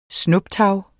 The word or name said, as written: snuptag